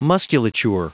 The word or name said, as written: musculature